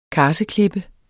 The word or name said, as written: karseklippe